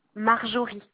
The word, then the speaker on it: Marjorie: Marjorie. MARJORIE. – Alors, Marjorie, c'est MARJORIE. – Non, J. – Ah, J. – J-O-R-I-E.